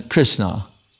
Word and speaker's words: Krishna: The Dan Sagar is in Krishna. 2 syllables. Anyone can say it.